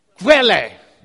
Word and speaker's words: Quelle: How do I say this? Quelle. Was that close? It's close enough.